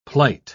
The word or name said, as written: plight